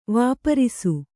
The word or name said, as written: ವಾಪರಿಸು